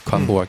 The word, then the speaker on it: Kraftwerk: Kraftwerk，啊，Kraftwerk 是德语的发音。